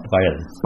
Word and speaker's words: Braille: Ja, Braille.